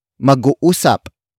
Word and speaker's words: mag-uusap: Word for word it's, mag-uusap.